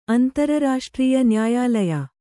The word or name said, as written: ಅಂತರ್ರಾಷ್ಟ್ರೀಯ